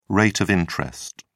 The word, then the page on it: rate of interest: British English